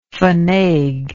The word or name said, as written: fainaigue